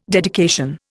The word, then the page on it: dedication: Indian